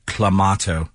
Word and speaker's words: Clamato: It's Clamato.